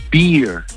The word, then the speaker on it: beer: Y este hace, ya le dice el camarero a mi amigo, le dice, no, you want a beer. Porque con, o sea, la barba se pronuncia beard, el oso se pronuncia bear, y la cerveza se pronuncia beer. Pero con R al final.